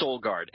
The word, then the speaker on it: Solgaard: Solguard, S-O-L-G-A-A-R-D, and Solguard Mobile.